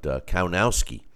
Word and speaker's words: Kownacki: That's how you say it, Kownacki. It's spelled like Konaki, but Kownacki. That's how they were saying it.